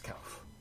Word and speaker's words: Kouf: Do we pronounce his last name Kouf? – Yes. – Jim Kouf. There we go. – Yes, Kouf.